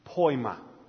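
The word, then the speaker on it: poiema: That word, workmanship, in the Greek it's pronounced poima.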